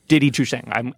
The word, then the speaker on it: Didi Chuxing: Didi Chuxing. Did I actually get the pronunciation? – Pretty much.